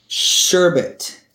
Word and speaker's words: sherbet: Sherbet. It is sherbet. – Well, it is spelled sherbet. You can say it how you want. I don't care. But if you are spelling it with an R after the second E, you are spelling it wrong.